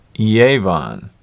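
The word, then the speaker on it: Yevan (יון): Now, there's an asterisk here in my Bible, and in Hebrew, the word translated in English as Greece is Javan, or in Hebrew, it's pronounced Yevan.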